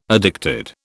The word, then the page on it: addicted: British English